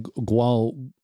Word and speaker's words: guayule: It's spelled G-U-A-Y-U-L-E. It's pronounced Y-U-L-E, although it looks like Guayule or for you Stargate SG-1 fans out there, it looks like, I want to say Guauld. Let's see if I can say this now. I'll say it correctly.